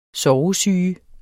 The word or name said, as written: sovesyge